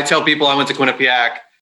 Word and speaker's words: Quinnipiac: And for those listening, that is Quinnipiac. Everyone's been butchering the name and all these polls and everything going on recently. But yes, it's Quinnipiac University.